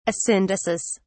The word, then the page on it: asyndesis: British English